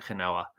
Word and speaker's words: Genao: G-E-N-A-O. I'm going to pronounce it Genoa. He is just it's racking Genao. Up. – Genao. I prefer Genoa.